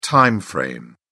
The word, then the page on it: time frame: British English